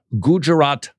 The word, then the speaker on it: Gujarat: Immediately after he became chief minister, the current Prime Minister of India was involved in what are called the Gujarat riots.